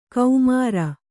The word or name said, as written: ಕೌಮಾರ